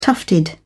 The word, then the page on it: tufted: British English